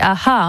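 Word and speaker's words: a-ha: C'est Aha, hein. On le prononcerait comme ça.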